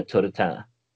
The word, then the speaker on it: Turretin: Det är Turretin. Alltså en slags eh, fransk engelskt uttal.